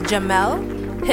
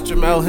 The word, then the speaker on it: Jemele: ESPN Sports Center host, I believe it's pronounced Jemele Hill. – Yes, Jemele Hill.